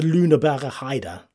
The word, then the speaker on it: Lüneburger Heide: The Green Eagle Golf Courses, it's a collection of 42 holes in total on the outskirts of Hamburg in the Lüneburger Heide. – Very good. – My finest German. – Whoa. – Well done.